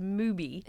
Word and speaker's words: MUBI: M-U-B-I. It is not spelled like movie. It is movie. – It's movie, like the platform, like the streaming service.